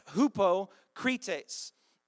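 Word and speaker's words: hypocrites (ὑποκριτής): It actually looks like our English word, right? Hypocrite. But it's pronounced hypocrites.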